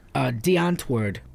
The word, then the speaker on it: Die Antwoord: It means the answer in Afrikaans. Die Antwoord. Yep. That's how they say it.